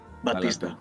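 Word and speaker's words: Bautista: Παιδιά, ο, ο, ο Dwayne Johnson, εντάξει, ταλαντούχο μεν, αλλά δεν είναι τόσο καλό όσο τον Μποτίστα. – Μπατίστα, όπω προφέρεται.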